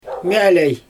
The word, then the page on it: mjAlej: Xyzyl